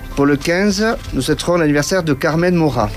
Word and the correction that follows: Maura: Alors, je ne dois pas bien le prononcer, puisque c'est une actrice espagnole. Carmen Maura, qui va fêter donc ses 72 ans.